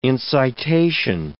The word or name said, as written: incitation